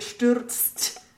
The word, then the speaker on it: stürzt: We really have to phonetically remember that the Z is a TS, and then there's a T at the end, so you get stürzt.